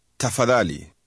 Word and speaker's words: tafadhali: Tafadhali.